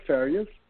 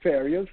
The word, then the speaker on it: Farias: I'm under Humberto Farias. My first name is spelled H-U-M-B-E-R. – Spell out your name. Humberto. – Yes. – Spell out your name. – It's H-U-M-B-E-R-T-O. My last name is Farias. That's F as in Frank. A-R-I-A-S.